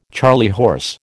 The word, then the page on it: charley horse: American English